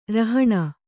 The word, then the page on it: ರೌಹಿಣ: rauhiṇa